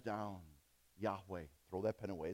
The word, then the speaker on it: Yahweh: Some people, they're Orthodox Jewish, very strict, and they're too afraid to say those four letters put together, which we would pronounce Yahweh.